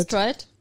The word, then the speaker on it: Stride: Stride oder Strid, ich weiß gar nicht, wie das ausgesprochen ich, also wird. Ich würde sagen, es ist Stride. – Stride.